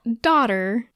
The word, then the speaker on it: dodder: And daughter, and I realize I've said that like 18 times now, it's spelled D-O-D-D-E-R. It's not like daughter, like a young, and like an offspring. Daughter. D-O-D-D-E-R.